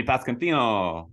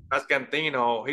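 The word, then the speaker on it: Pasquantino: Vinny Pascantino. – Pascantino.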